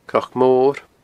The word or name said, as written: cnocmor